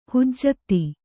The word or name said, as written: ಹುಜ್ಜತ್ತಿ